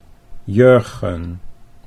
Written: Jurgen

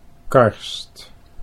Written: Karst

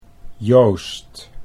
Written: Joost

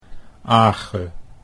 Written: Age

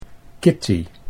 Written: Kitty

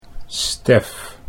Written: Stef

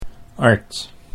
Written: Ard

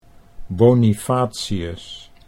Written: Bonifatius

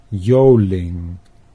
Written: Joling

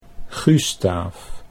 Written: Gustaaf

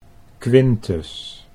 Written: Quintus

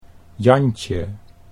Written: Jantje

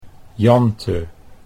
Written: Jante

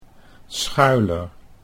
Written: Schuyler

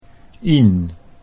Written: Ien

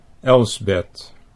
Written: Elsbeth

